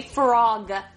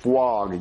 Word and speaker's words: frog: No, see, Jeffy, you're saying it wrong. You're saying fwog. You gotta say frog. – Fwog. – No, there's no W. You're saying fwog. You gotta say frog. Frog. – Fwoga. Fwoga. – No, that's not how you say it. You say frog.